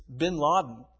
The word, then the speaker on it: Ben Laden: Do you say Bin Laden? – It's Bin Laden. That's how you say it in French.